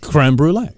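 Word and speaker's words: crème brûlée: It's a name, because it's pronounced in French, creme brulee.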